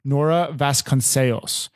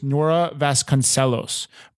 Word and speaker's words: Nora Vasconcellos: The one way that I just said it is the correct way to say it, but I'm saying it in somewhat of a like a Spanish Portuguese accent. Nora Vasconcellos.